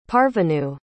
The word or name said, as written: parvenue